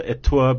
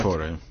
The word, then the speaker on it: Ettore: Founder Ettore Bugatti. Am I saying that right? – No, you're not. Ettore.